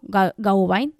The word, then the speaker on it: Gawain: Estamos en proceso de eh, leer Sir Wayan, no, Gawain, ya lo está pronunciando mal,